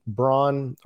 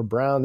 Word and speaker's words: Braun: Christian Braun or Brown, they were pronouncing it Brown, but it reads like Braun on the broadcast.